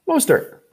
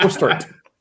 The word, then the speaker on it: Mostert: Mostert. The T is silent. It's not Mostert.